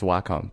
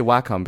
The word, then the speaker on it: Wacom: I read somewhere that, yeah, there is some official source that says, yes, it's Wacom, but I think the companies, like, you know, they're like, we don't really care, just buy our products, but yeah, we say Wacom, so there it is.